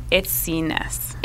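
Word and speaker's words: Itsines: I don't know how to say her last name. – It's Itsiness. – Itsiness. Okay, yeah.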